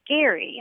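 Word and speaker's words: Gerry: I wanted to ask you guys about gerrymandering because I recently learned that the person who it's named after pronounced his name Gary, and I wonder why we never said gerrymandering.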